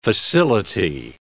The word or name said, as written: facility